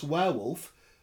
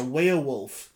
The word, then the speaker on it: werewolf: Guy Wilson said, to answer the most important question in this episode, I pronounce werewolf the same as Dr. Kate, i.e. like the river Weir. – Yes. – The werewolf.